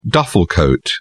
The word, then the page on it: duffel coat: British English